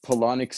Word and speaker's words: Palahniuk: Palahniuk sounds like colonic, but it's spelled really weird. Palahniuk, or something like that.